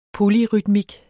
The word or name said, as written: polyrytmik